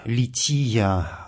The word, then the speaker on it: лития: Правильное церковнославянское название вот этой части богослужения – «лития». Здесь ударение совпадает с греческим словом «лити».